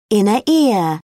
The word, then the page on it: inner ear: British English